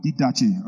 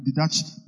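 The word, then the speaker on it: Didache (διδαχή): Greek word didache. Rabbis. How do you pronounce it? Didache. Didache. D-I-D-A-C-H-E. D-I-D-A-C-H-E.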